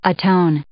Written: atone

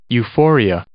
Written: euphoria

